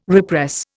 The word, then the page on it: repress: Indian